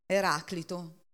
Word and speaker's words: Eraclito: Eraclito, io ce l'ho con l'accento sulla prima A, quindi è giusto.